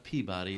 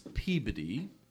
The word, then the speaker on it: Peabody: It's not Peabody, it's Peabody. And Quincy is Quincy, is how you say it.